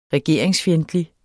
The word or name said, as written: regeringsfjendtlig